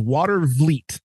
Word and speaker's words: Watervliet: They butcher their French. It's water vleet.